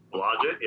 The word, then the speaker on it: Blodgett: Blodgett, yep.